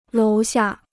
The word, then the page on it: 楼下: lóu xià